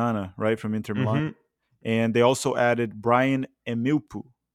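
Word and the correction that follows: Mbeumo: Brian Mbuemo.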